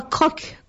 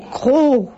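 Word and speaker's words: Crocq: On ne dit pas croque, comme j'ai dit dans la Creuse, mais croc.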